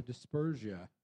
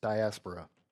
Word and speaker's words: diaspora: And they were bringing different currencies because they were part of the dispersia. We can all use big words. – Diaspora.